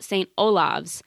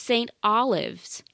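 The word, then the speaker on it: St Olave's: This one was called we called it, and I still have the habit when I see it written, to call it St. Olav's. According to the internet, the almighty internet, it is pronounced St. Olives.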